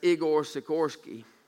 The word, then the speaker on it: Igor Sikorsky: I'm thinking I pronounced his name right. It's Igor Sikorsky.